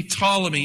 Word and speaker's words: Ptolemy: P, maybe you don't pronounce the P, Ptolemy, Egypt.